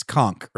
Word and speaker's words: conch: Is it a CH sound, or is it, it's conch, right? – I've always said conch.